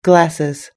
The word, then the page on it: glasses: British English